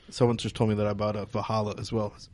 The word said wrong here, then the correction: Valhalla: Am I saying that right? I feel like I'm saying that wrong. – That's sacrilegious. You can't say it like that. – Valhalla.